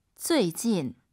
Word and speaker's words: 最近: 最近. – UI 사이에 에 넣으셔야 돼요. 우리 선생님 발음 들어보세요. 쭈이진, 쭈이진이 아니에요. 잘 들어보세요.